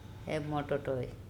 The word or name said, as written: ErmOTOToy